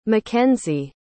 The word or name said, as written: mikenzie